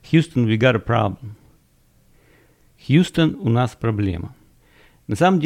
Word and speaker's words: Houston: Хаустон, вернее, оно пишется Хаустон, а читается Хьюстон.